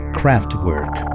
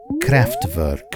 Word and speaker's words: Kraftwerk: You can talk about electronic music without mentioning Kraftwerk, or, as they pronounce in Germany, Kraftwerk.